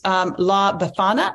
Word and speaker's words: La Befana: And then maybe in honor of the holidays, La Befana. Am I saying that right? – Right. Correct.